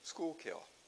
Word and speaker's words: Schuylkill: It doesn't look like Schuylkill. Schuylkill to me would be spelled S-C-H-O-O-L-K-I-L-L. Schuylkill.